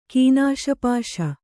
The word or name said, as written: ಕೀನಾಶಪಾಶ